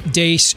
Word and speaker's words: Deace: You can like us on Facebook and follow us on Twitter at Steve Dace Show. Last name is spelled D-E-A-C-E.